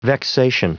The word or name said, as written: vexation